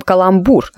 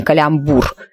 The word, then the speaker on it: каламбур: Между прочим, каламбур, а не калямбур.